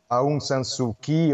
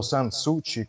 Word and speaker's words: Aung San Suu Kyi: Um, ehm, o, o, desprezo pela, pela inteligência da opinião pública nacional e mundial, neste momento Aung San Suu Kyi, ou San Suu Kyi, como parece que se pronuncia, uh, fica eh, em regime de detenção pela importação ilegal de walkie-talkies, que parece.